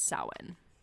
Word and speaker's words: Samhain: It's actually all of those are wrong. The Celtic pronunciation is Samhain.